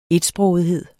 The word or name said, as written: etsprogethed